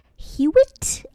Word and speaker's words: Hewitt: It is Saturday the 17th, and it's going to be at 428 South Hewitt. I don't know how to say this. This is H-E-W-I-T-T Street. – Oh, yeah, Hewitt. – Hewitt? Okay,